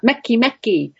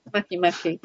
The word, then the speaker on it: Makemake: Then there's Maki Maki, and I think you pronounce that Meki Meki, but it's awfully hard to keep going Meki Meki, so I'll just go Maki Maki.